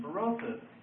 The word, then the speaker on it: Berossus: His name is Berossus, another ancient historian, Berossus. And that's spelled B-E-R-O-S-U-S, Berossus.